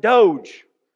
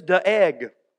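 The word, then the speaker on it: Doeg: Last week I pronounced it Doge. I had practice all week. And really the pronunciation is de-egg.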